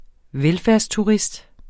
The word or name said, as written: velfærdsturist